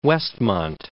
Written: Westmont

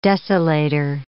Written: desolator

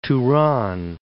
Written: Tourane

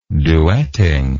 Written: duetting

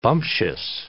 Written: bumptious

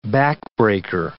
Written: backbreaker

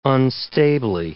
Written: unstably

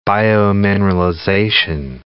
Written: biomineralization

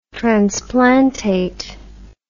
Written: transplantate

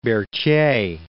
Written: Berthier

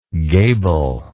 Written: gablelike

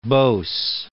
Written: Bose

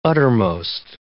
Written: uttermost

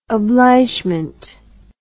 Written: obligement

